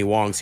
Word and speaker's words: Hoang: So anyway, his last name is spelled the same way as Anthony Wong's here, and they pronounce it Wong.